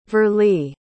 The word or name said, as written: verlie